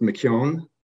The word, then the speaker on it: McKeown: It's pronounced McKeown, but you're close enough.